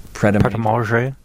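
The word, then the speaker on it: Prêt à Manger: Pret-a-Manger, is that right? – Pret-a-Manger.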